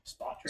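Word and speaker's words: Spotrac: The one thing, how do you say this? The spot track? Spot track? – Yeah, it's spot track.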